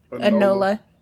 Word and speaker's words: Enola: Enola. – Enola.